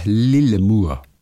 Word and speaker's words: Lillemor: Der Buchstabe O wird im Schwedischen eher wie unser U ausgesprochen. Das A mit dem kleinen Kreis oben drüber dagegen wird als O ausgesprochen und das U wird zum Ü und so weiter und so fort. Lange Rede, kurzer Sinn. Lillemor spricht sich also eher Lillemur.